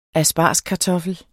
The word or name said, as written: ASPARGESKARTOFFEL